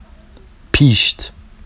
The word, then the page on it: piʃt: Vafsi